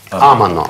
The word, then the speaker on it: amano: Амано, амано.